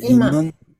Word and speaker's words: Imane: Şimdi çevrim içinde İman diye okunuyor herhalde. – İman. – İman Halef. Halef. – İman Halef. Okey, tamam.